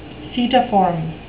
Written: setiform

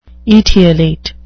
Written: etiolate

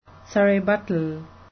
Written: surrebuttal

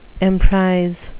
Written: emprise

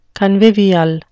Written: convivial

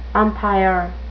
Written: umpire

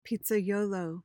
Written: pizzaiolo

